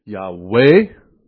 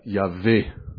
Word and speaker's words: Yahweh: et nous aurons la prononciation « Yahweh » ou, comme on dit en français, « Yahvé ». Donc, 2 façons de prononcer, en fait, le même mot hébreu. « Jéhovah » ou « Yahvé ».